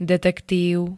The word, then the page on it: detektív: [d-t-t-]